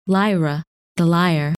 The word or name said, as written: Lyra